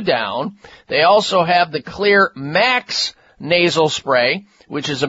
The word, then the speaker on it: Xlear: Make sure your nasal hygiene, just like your dental hygiene and all the rest of your hygiene, is up to speed with clear nasal sprays. Clear is spelled X-L-E-A-R, and you can find clear products, including clear nasal sprays.